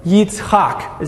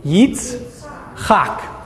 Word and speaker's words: Yitzchak: In English we say Isaac, right? I have a new nephew and his name is Isaac, so I have a new level of love for that name. But Yitzchak is how you say it in Hebrew. Yitzchak.